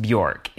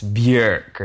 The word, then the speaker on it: Björk: Her name is not pronounced Björk. I think it's Björk or something. Rhymes with work.